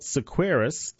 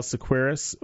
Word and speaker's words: Seqirus: CSL Sequeiros, that's spelled S-E-Q-I-R-U-S, has appointed Jonathan Kegerize as vice president of manufacturing, where he will lead a team of more than 950 employees.